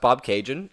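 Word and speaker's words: Bobcaygeon: Ryan didn't know how to pronounce the word, and I was like, it's pronounced Bob Cajun, exactly pronounced and spelled, like the Tragically Hip song.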